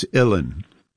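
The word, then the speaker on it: uilleann: It's Ilin. Like chillin, illin.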